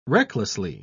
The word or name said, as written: recklessly